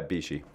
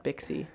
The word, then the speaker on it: BIXI: Yeah, Bichy. – Yeah, Bichy. – I wasn't about to say because I didn't know. – B-I-X-I. – Yeah. – Yeah. – Not Bixie.